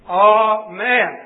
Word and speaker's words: amen: And that means, according to this particular Bible, which I believe is correct, the first letter is pronounced as in A-H, ah, as in father, F-A-T-H-E-R. So that the correct pronunciation of the word is unquestionably amen.